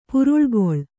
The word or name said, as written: ಪುರುಳ್ಗೊಳ್